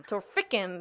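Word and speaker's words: Torphichen: How do you say that one? The T one. Tofenshin. – Torfiken, there you go.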